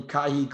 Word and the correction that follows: Kihei: Clark? Kihei Clark, who distributes the ball well.